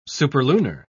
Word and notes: superlunar: British English

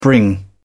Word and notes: bring: British English

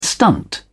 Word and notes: stunt: British English

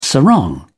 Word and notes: sarong: British English

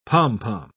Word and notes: pom-pom: British English

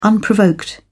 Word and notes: unprovoked: British English

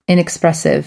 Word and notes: inexpressive: American English

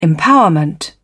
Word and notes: empowerment: British English

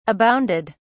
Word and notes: abounded: British English

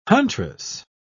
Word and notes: huntress: British English

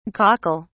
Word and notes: cockle(s): British English

